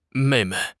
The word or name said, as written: mei4 mei5